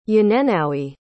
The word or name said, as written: yanenowi